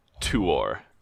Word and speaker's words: Tuor: I do believe it is pronounced Huor and Tuor.